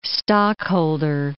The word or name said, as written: stockholder